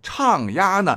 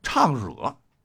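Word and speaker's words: 唱喏: 所以，研究这个四大名著的专家就说了啊，你看，行插手礼跟古代唱押一样，已经成为了人们生活交往中的规范礼仪。哎，说到这，又有一个名词，什么叫做唱押呢？押就是口字旁一个若非的若啊，经常被人读作唱惹。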